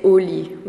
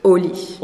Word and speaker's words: au lit: We would say au lit, which is, you know, in bed. – So the pronunciation now is? – Au lit. – Au lit. – Instead of en lit.